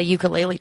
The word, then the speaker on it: ukulele: It's called a not a ukulele. Ukulele is the actual pronunciation.